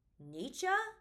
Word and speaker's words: Nietzsche: Okay, I looked up how to say his name, and apparently it's Nietzsche, but I don't think anyone would know who I was referencing if I said it correctly, because every time I've ever heard his name, it's Nietzsche.